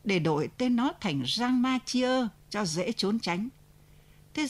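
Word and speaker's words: Champmathieu: Ở đấy tiếng răng, giọng địa phương đọc là xăng, cho nên mới gọi là Sang Ma Chia.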